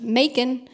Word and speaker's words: Macon: I recorded the audiobook, and the people at Penguin Random House wrote back to me on a couple things and said, hey, you mispronounced Macon. And I was, it's supposed to sound like bacon.